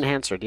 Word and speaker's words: Hansard: They turned it into a Broadway show, but it's Glenn Hansard. Is that how you say his last name? – Glenn Hansard, yeah.